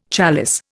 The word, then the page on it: chalice: Indian